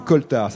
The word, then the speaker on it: coaltar: Et c'est ça, c'est coltard. Ça s'écrit C-O-A-L-T-A-R, le coltard.